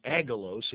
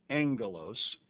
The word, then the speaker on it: aggelos: It's agelos if you spelled the word, A-G-G-E-L-O-S, but it's pronounced angelos.